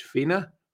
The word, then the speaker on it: Fine: Because it's a F-I-N-E, pronounced Fina or Fianna, and G-A-E-L.